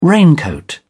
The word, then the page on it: raincoat: British English